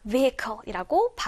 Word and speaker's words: vehicle: 자, 두 번째 단어, 탈 것이라는 의미 혹은 수단이라는 의미를 가지고 있는 단어는 강세가 앞에 있기 때문에 vehicle이라고 발음하시면 돼요.